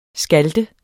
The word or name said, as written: skalte